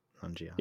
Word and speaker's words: Nanjiani: Kumail Nanjiani? Is that how you say his name? – Nanjiani.